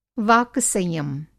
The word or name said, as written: वाक-संयम